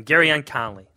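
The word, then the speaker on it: Gareon Conley: Marshon Lattimore, Malik Hooker, and everybody's forgetting about, I can't even say his first name, Gerrion Conley. – Yeah.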